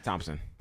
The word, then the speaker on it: Thompson: Thompson. – Thompson? – Yeah, Tristan Thompson. Thompson. Yeah, Thompson. – Thompson. – Not Thompson. – Thompson. You don't pronounce the H.